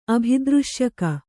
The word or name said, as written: ಅಭಿದೃಶ್ಯಕ